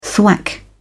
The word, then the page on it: thwack: British English